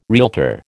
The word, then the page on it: realtor: American English